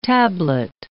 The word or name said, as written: tablet